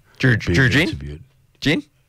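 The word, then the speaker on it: Drew Ginn: There was I heard a rower, an Olympic, Australian Olympic rower called Drew Glynn. – Drew Gin? – What's his name?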